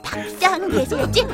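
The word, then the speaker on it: 박장대소: 와, 아, 아, 뭉치야, 너 정말 지야, 너 설마 박장 대소를 말한 거야? 막장이래. – 막 이게 막장이다. 아, 아, 그렇지. 아, 아, 박장 대소였지. 어, 어 바, 바, 바, 발음이 꼬여서.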